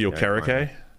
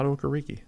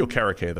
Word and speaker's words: Okereke: Bobby Okereke. – I forgot he changed his pronunciation. – Yeah. It's not Okereke. – Not anymore.